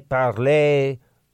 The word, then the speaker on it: Parler: David, is the social media site you've been talking about Parler or Parlay? Well, David, it's spelled Parler, I believe, P-A-R-L-E-R, which technically, if you are somebody who parlez-vous français, you would say Parler.